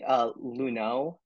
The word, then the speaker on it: Lunot: It's actually Lunot. It's French, right? – But...